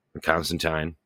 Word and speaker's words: Constantine: You know, always going to be inclusive and have a Joanna Constantine, Constantine, as it's pronounced in the show.